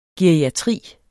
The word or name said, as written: geriatri